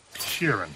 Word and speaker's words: Sheeran: Frank Sheeran.